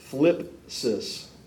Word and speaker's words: thlipsis: It's a word pronounced thlipsis, T-H-L-I-P-S-I-S, thlipsis.